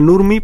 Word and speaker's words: Nurmi: Nurmi es una vampira, se pronuncia Nurmi porque rinde homenaje a la actriz Mayla Nurmi,